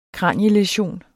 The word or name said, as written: kranielæsion